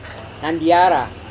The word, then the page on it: nandiara: Serewen